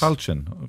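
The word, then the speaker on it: Tailteann: On the, pronounce it for me. I got that wrong. I placed the Talchin wrong games. – Talchin. Okay. All right.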